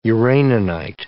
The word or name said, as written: uraninite